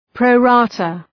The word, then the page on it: pro rata: ,prəʋ'rɑ:tə